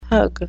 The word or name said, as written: pug